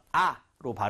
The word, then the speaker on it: hour: h가 들어가 있지만, h는 묵음이 되겠죠? 그래서, 아로 발음을 하셔야 됩니다. hour라고 하면 절대로 안 되겠죠.